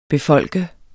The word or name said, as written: befolke